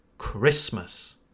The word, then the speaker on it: Christmas: But if you want to sound more proficient, more advanced in English, then it's better to say Christmas. Christmas. Again, we place emphasis on the first syllable. So it's Christmas, not Christmas. Christmas.